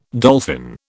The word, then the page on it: dolphin: British English